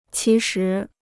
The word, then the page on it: 其实: qí shí